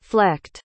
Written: flecked

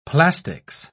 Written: plastics

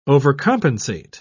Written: overcompensate

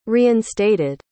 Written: reinstated

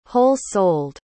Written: whole-souled

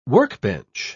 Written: workbench

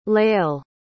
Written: lael

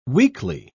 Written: weekly

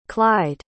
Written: clide

